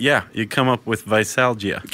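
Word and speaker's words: veisalgia: Visalgia? – Yeah. – Is that the correct pronunciation? – That's how I took it. – That's the medical medical name for hangover is visalgia.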